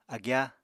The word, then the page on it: agyá: Apatani